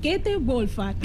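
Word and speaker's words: Käthe Wohlfahrt: Com s'ha de pronunciar el nom d'aquesta botiga? Perquè hem tingut problemes a la redacció. – Bueno, cadascú ho pronuncia d'una manera diferent, però es diu Käthe Wolfart.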